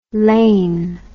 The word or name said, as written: Laine